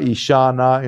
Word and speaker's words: Ishana: Icha from Icha Shakti corresponds to the first E, to the short E, but the fourth one is E long E, and that's Ishana, Isha, from Ishvara.